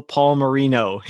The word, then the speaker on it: Palmerino: Today, my guest is Enrico Palmerino. He helped me pronounce his name by saying it's like if Dan Marino and Paul had a baby.